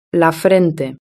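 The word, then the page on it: la frente: Spanish (Spain)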